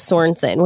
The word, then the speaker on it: Sorensen: You know, I have to just say really quick, my last name is Sorensen, which is incredibly like basic. Mm. And so many people say Sorensen,